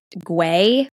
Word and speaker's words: Güell: And something else that Gaudi designed was Park Güell, which it might be pronounced, it's G-U-E-L-L. It might be pronounced Güell because the double L in Spanish isn't the hard L sound. But Hallie and I are used to saying Guel, so that's what we're going to say. So sorry if we offend anyone who knows that that's not actually how you say it.